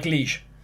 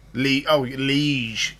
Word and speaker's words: liege: Leech lord, I've not heard that word before. – You know, like liege. – Liege. Yeah, liege.